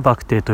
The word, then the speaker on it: 肉骨茶: たちには、まあ、知ってる人も何人かいると思うんですけどバクテっていうのはと肉漢字の肉に漢字の骨にお茶漢字のお茶茶ですね肉骨茶だったかなって書くんですよでバクテ